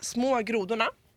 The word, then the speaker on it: Små grodorna: How do you pronounce it again? – Små grodorna. – Små grodorna. – Grodorna. – Grodorna. – Yeah, that's good.